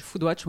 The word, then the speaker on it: Foodwatch: Vous êtes directrice générale de l'ONG Foodwatch, on prononce Foodwatch ? – Foodwatch, oui, Foodwatch France.